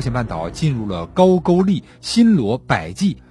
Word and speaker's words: 高句丽: 几十年后的公元三年，第二代王琉璃明王迁都到今天的吉林吉安，并且正式定国号为高句丽。勾呢就是句号的句，呃，一个多音字。